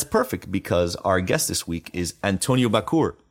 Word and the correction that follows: Bachour: i'm sorry i'm gonna re- i'm gonna do that again our guest this week is antonio bachour because his name is pronounced like a sneeze